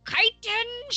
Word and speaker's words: chitin: Chitin shield! – Chitin. Titan. – Chitin rhymes with Titan.